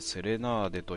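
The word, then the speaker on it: 小夜曲: で、その 36 話目ですけども、あれなんだっけ、この人なんて言うんだっけな、セレナーデだっけ、さよ曲と書いてセレナーデと読む、そうだな、合ってた、さんで道連れっていう題名の話からいきたいと思います。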